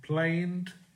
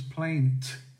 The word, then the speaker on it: planed: P-E-R-T. Planed, you could plane something, could plane wood, but if you pronounce a T, it becomes plaint.